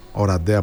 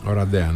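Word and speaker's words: Oradea: Οραντέα, όπως προφέρετε. – Οραντέα, ναι.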